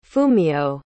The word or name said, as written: fumiyo